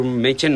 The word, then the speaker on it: Machen: bueno, se pronuncia Arthur Machen, pero bueno, ya sabemos.